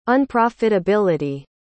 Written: unprofitability

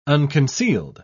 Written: unconcealed

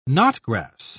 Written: knotgrass

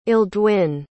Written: eldwin

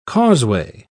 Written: causeway

